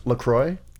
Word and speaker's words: La Croix: It's pronounced La Croix.